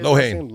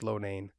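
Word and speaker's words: Loughnane: I know it doesn't look that way, but it's Lohane. – No, yeah, I keep thinking it's Lohane, but everyone keeps telling I, I the way it's spelled looks Lonane.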